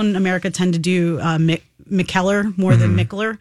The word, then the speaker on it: Mikkeller: So Mikkeller.